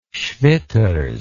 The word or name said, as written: schwitters